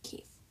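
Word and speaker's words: Keefe: Keef. – Yay! Because Keef is how to say it properly, apparently.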